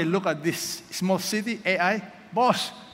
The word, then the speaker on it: Ai: By the way, I pronounce this as AI. Okay? So Joshua sent people to AI. It's up to you. We don't have to argue to death. You want to pronounce this as I? Okay. I? Okay. I pronounce it as AI.